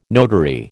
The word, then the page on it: notary: American English